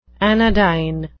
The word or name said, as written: anodyne